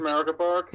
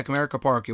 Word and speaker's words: Comerica Park: Comerica Park. – Yeah, Comerica Park.